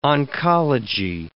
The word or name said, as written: oncology